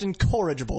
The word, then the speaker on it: incorrigible: It's pronounced incorrigible. It's a hard O, fuckface.